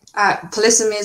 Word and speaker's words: polysemy: You can begin by correcting my pronunciation if I've got that wrong. But maybe before we get to the issue itself, could you maybe tell the audience, for viewers or listeners like myself who didn't know this word, what polysemy means? – Polysemy is the right pronunciation, I think.